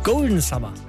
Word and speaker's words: Golden Summer: Ich hole den Sommer zurück, gleich mit Loy und David Puentes, Golden Summer. Und vielleicht spricht man es auch ja Englisch aus, merke ich gerade. Golden Summer heißt das Ding wohl eher.